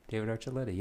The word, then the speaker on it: Archuleta: David Archuleta. Did I say it right? – David Archuleta, yeah, that's David his name.